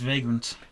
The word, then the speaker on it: Vagrant: Bah, surtout que ça se prononce Vagrant.